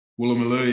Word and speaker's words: Woolloomooloo: And we stayed in I don't know if I pronounced it right, but was it Woolloomooloo? – Woolloomooloo, yeah.